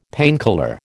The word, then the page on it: painkiller: American English